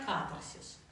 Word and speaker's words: катарсис: По-разному проговаривают катарсис, катарсис, но лучше катарсис. Чисто орфографически, фонетически, правильно, катарсис. Ударение на первую А.